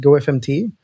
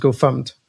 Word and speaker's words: gofumpt: because I used to call the original Go Fumped Go FMT. Mm-hmm. And then somebody told me, no, no, it's pronounced Go Fumped.